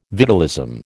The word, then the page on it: vitalism: American English